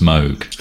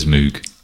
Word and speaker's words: Moog: We won't get into the fact it's supposed to be pronounced Moog. – Is it? – Yeah. But everybody says Moog.